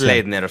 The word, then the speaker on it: Laettner: que se llama I Hate Christian Leitner, yo odio a Christian Leitner, no Leitner, Leitner, Qué, difícil. – No, es, es, Leitner, o sea, Leitner. es un nombre alemán.